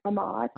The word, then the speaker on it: Ahmaud: Ahmed. Am I saying his name right? Ahmad. Ahmad. – Ahmad. – I don't know why I always want to say Ahmed.